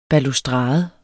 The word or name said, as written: BALUSTRADERNES